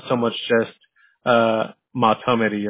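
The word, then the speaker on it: Mahtomedi: Mato Midai. – Mato Midai. Wow, that is so like a very phonetic with like a long I there. I didn't expect a long I at the end.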